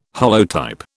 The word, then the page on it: holotype: British English